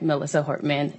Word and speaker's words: Melissa Hortman: In my remarks, I had mispronounced Melissa Hortman, House Speaker's name, and I just want to stand corrected on clarifying that Melissa Hortman is who I was referring to.